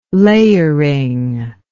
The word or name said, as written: layering